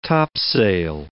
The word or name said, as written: topsail